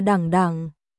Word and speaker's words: đẳng đẳng: Một, đen đen từ này đọc là đẳng đẳng, cũng có nghĩa là từ từ.